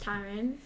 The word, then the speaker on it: Tyrann: Oh, by the way, this guy claims to be the biggest fan of Tyrann Mathieu, but he used to pronounce his name Tyran or Tyron. – Yeah, Tyron. I know it's Tyron. It's Tyron, but it's okay.